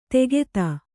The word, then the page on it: ತೆಗೆತ: tegeta; Kannada